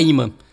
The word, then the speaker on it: Yma: Ela é a faixa título do primeiro álbum da Ima, que se escreve Y-M-A.